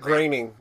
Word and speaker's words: Groening: Groening.